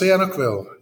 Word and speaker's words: Sihanoukville: After 2 years working at a small English language school in, and you'll have to forgive me for the pronunciation, Sihanoukville? – Close, Sihanoukville, yeah.